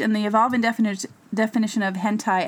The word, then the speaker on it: hentai: Okay, number 10, the last one, and forgive me if I'm mispronouncing this, hentai? H-E-N-T-A-I. – Hentai, okay, yeah.